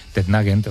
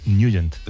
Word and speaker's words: Nugent: Hombre, siempre lo hemos llamado en el barrio Tednagent o Tednagent. – Claro, ¿no? Yo era de Tednagent. – Pero la pronunciación correcta es Nugent.